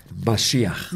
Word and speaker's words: Mashiach: How do we say that? Mashiach? – Mashiach.